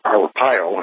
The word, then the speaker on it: Arpaio: Here in Maricopa County, Sheriff Joe Arpaio, I believe that's how you pronounce his name. – Yes.